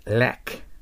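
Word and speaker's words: luck: あとへの中間の音を強く出してください。 lack。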